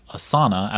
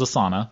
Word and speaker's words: Asana: And so that everybody knows, they pronounce their name as Asana as opposed to the yoga flow, which is Asana. And so they are mispronouncing on purpose, and it is Asana.